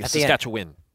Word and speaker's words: Saskatchewan: My problem with Saskatchewan is I feel like I say it like an American. – Yeah, you have to Win. Like Saskatchewan. – Saskatchewan. – Yeah. – There you go.